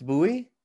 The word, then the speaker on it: Bouye: I think moving away from AJ Bouye. How am I pronouncing that correctly? Anyway, AJ Bouye, I think. – Yeah, Bouye. It's Bouye.